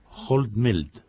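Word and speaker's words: hot melt: Pero, profesora Esteban, hoy vamos a hablar de los adhesivos, corríjame si lo pronuncio mal, Holtmelt. – Sí, así es.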